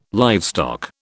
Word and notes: livestock: British English

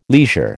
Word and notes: leisure: American English

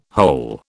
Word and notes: whole: British English

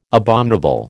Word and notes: abominable: American English

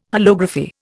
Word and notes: holography: Indian

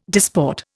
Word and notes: disport: Indian

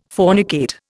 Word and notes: fornicate: Indian

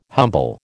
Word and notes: humble: American English

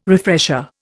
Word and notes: refresher: Indian

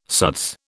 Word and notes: suds: British English

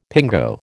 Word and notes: pingo: American English

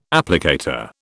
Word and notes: applicator: British English

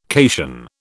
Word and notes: cation: British English

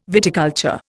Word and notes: viticulture: Indian

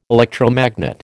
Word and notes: electromagnet: American English

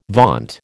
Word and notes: vaunt: American English

